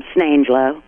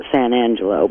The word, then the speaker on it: San Angelo: But if you live there, it's pronounced, where are you from? Snangelo. It becomes one word. It's not San Angelo.